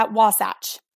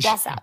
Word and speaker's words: Wasatch: Um, and we're going to be running a, a, marathon, obviously the one that you're running out in, is it, how do you say it? Wasatch? Wasatch? – Yeah, Wasatch. – Wasatch. – Wasatch. – We've been yep. saying it wrong this whole time.